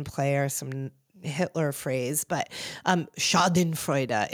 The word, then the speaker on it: Schadenfreude: It's Schadenfreude.